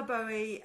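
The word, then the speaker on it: Boë: So Silvius de la Bowie, but it's spelled B-O-E, umlaut.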